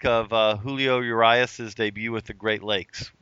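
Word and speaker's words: Urías: And am I pronouncing that right, Ben, Urias? – Yeah, Julio Urias.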